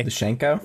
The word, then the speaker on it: Lukashenko: how do you say his last name? Anyway. – Lukashenko? – Yeah.